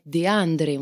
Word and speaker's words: Desandre: En Italie, euh, mon professeur me disait tout le temps, mais ça devrait être De André.